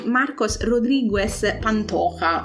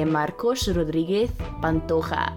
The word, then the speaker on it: Marcos Rodriguez Pantoja: E questa è la storia proprio di Marcos Rodriguez Pantoja. Bene, dimmi, dammi un voto. – E devo correggerti la pronuncia, perché Marcos Rodriguez Pantoja.